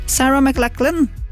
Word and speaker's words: Sarah McLachlan: Endagsfestivalen har altså kun kvinnelige artister på plakaten, en festival inspirert av Lilith Fair, kvinnefestivalen som Sarah McLachlan. Sarah McLachlan. Sånn uttaler jeg det. Ok, arrester meg om du vil.